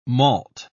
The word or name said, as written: malt